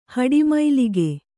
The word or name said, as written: ಹಡಿಮೈಲಿಗೆ